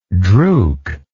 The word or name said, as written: drouk